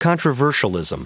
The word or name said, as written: controversialism